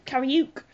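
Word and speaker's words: Kariooq: Kariuk. – That's K-A-R-I-O-O-Q for the listener.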